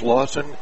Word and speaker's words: Lausen: It is Lawson Flores, spells the first name L-A-U-S-E-N, pronounced Lawson, F-L-O-R-E-S, Flores, 5'9", junior, showed some quick legs.